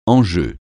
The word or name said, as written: enjeu